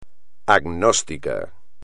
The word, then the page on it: agnóstico: espagnol